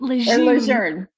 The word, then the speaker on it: Lejeune: We were just Lejeune. Talking about how to pronounce it. Lejeune.